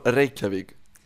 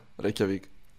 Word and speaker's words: Reykjavik: It's not that bad. It's like more Reykjavik. Like, Reykjavik? – You know, Reykjavik, yeah, with that E and the, like, yeah, Reykjavik.